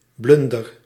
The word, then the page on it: erreur: /ɛ.ʁœʁ/